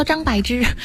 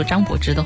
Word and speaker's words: 张柏芝: 如果我在节目当中一直说张柏芝的话，我会被罚，我会被罚。所以我要告诉大家，这个字读音是正确的读音，它只有啊只有一个读音是柏，它的另外一个读音柏仅限于地名柏林的时候，好吧？